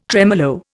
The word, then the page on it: tremolo: Indian